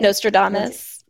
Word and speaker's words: Nostradamus: Nostradamus, is that, did I say his name right? – Nostradamus. – Yes. Yeah, Nostradamus.